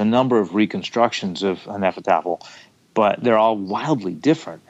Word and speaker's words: Hnefatafl: I was basing it on a historical Viking game, which is pronounced something like Hnefatafl.